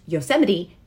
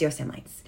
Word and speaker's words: Yosemite: and never pronounced Yosemite as Yosemites.